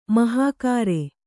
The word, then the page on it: ಮಹಾಗಾರೆ: mahāgāre